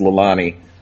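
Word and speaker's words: Llanelli: And they are out of Wales, just a little town which you can't really pronounce, but phonetically it's Lelani, just outside of Swansea in Wales.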